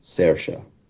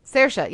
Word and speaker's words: Saoirse: The name is pronounced Saoirse. – Saoirse, yeah, that's right.